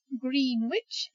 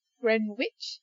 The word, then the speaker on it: Greenwich: Number 9, somewhere where I spent a bit of time, is not Greenwich, it's Greenwich. And talk about GMT, Greenwich Mean Time. You don't pronounce the W here, so it's not Greenwich. And although you have the word green in there, you don't pronounce it as green, it's Greenwich. So you get rid of an E in green and you get rid of the W, so it just sounds like Greenwich.